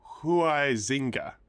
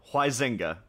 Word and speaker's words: Huizinga: How do you say it? – The way that I understand how to pronounce it now, Wayne... Huizinga. – You're full of shit. – So like Hawaii, like the company.